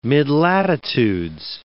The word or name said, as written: midlatitudes